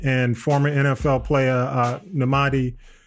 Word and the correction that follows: Nnamdi: uh, I'm sorry, Nnamdi, Nnamdi Asomugha.